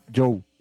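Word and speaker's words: Zhou: En este momento está haciendo tanto frío en el lugar en el que está metido en un turco con el chino, con Yu, que así es que se pronuncia, supimos hace poco, con Yu, Joe.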